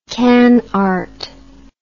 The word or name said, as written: canart